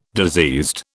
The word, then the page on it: diseased: British English